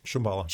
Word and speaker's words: Shambhala: Shambhala.